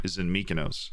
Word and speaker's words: Mykonos: Well, our recording studio is in Mykonos, so that's where we are right now, right? Is that how you? That's how you pronounce it. I can confirm.